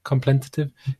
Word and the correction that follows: contemplative: How do I? – Contemplative? – Contemplative. Contemplative.